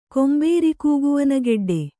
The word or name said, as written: ಕೊಂಬೇರಿಕೂಗುವನಗೆಡ್ಡೆ